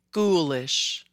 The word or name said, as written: Ghoulish